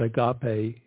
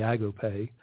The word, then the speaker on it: agape: We call that agape here in the South, but the correct pronunciation is actually agape, love that we cannot fathom.